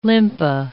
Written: limpa

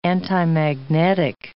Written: antimagnetic

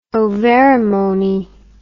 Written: ovarimony

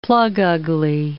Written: plug-ugly

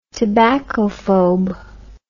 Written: tobaccophobe

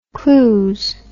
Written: cluse

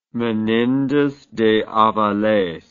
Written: menendez de aviles

